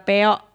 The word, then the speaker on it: peok: Piok ya teman Produa, peok, peok, peok. Bukan penyok, peok ya teman Produa, cicit.